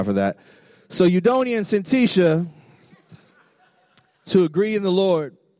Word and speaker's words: Syntyche: And I was just feeling a little extra ethnic today, so it's Syntyche.